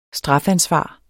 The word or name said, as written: strafansvar